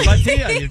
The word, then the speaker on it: Bhatia: Yes, Batia, you nailed it.